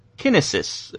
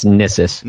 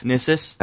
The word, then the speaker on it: Cyniss: And in New Zealand, Kinesis, or C-Y-N-I-S-S? – It's Nissus. – Nissus? – I believe is how we finally decided to pronounce his name.